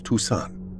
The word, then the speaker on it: Toussaint: "You pronounce her last name as Toussaint."